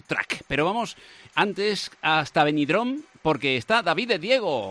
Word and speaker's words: Benidorm: Luz de Cruce en COPE, COPE Valencia, COPE Intercomarcas, COPE Alcoy, para toda la comunidad valenciana, incluida Benidorm. Y lo he dicho bien. Hola, ¿qué tal? ¿Cómo estás? – ¿Lo he dicho bien, Vicente? Lo has dicho perfectamente.